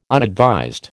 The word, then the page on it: unadvised: American English